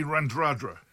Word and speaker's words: Radradra: But my back is Semi Radradra. – Randrandra. – My back is Semi... Shut up. Do you speak Fijian or not? – Well, I can pronounce it, yeah. – It's Semi Randradra.